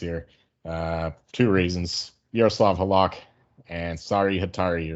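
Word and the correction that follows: Harri Säteri: or whatever you say his name. – Harry Sitari, that's it.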